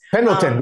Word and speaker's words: Pendleton: Pendleton, we call them Pendletons. – Pendleton. Okay. Pendleton? – Yeah. – Am I saying it right? Pendleton? – Yeah, the Pendleton shirts, yeah.